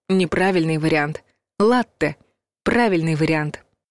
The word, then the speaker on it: латте: Слово «латте» обычно пишут правильно, с двумя буквами «т», потому что мы будто бы и произносим удвоенный твердый звук «т», а вот с ударением беда. Верно ставить его на первый слог.